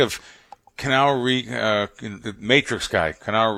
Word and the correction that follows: Keanu: Am I saying his name right? Keanu Reeves? – Keanu Reeves. – Keanu. That's the way you say Keanu.